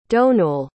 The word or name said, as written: domhnull